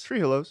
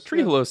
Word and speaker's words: trehalose: Is that how you pronounce it? Trehalose? – Trehalose. – Trehalose sounds good.